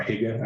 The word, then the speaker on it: hygge: So in the United States, many people know the concept of hygge from Denmark, or hygge, I think most people will pronounce it.